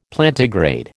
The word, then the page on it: Plantigrade: American English